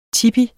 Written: tipi

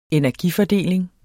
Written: energifordeling

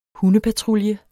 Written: hundepatrulje

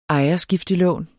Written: ejerskiftelån

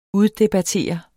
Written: uddebattere

